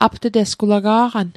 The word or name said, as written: apt'tesg